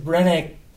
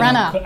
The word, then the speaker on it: Branagh: Äh, und haben uns den Mord im Orient Express von Kenneth Branagh, genau. Branagh angetan. Branagh.